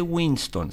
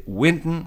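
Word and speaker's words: Wynton: A continuación, y haciendo un poco gala de que viene Winston Marsalis, no confunda con Winston, no se dice Winston, se dice Winton Marsalis, Winton. Winton va a estar tocando,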